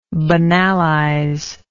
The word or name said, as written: banalization